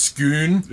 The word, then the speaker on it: Scone: It's spelled scone, but it's pronounced skoon.